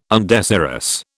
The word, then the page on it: undesirous: British English